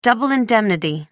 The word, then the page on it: double indemnity: American English